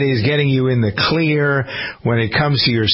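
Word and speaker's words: Xlear: There's a system from a company called Clear. It isn't spelled with a C, it's spelled with an X. X-L-E-A-R.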